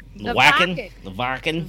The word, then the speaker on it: Wacken: It's the Wacken.